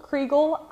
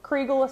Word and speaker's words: Kriegel: You can find me on Instagram at Nicole Kriegel, IPE Pro. Kriegel is spelled KrieGel.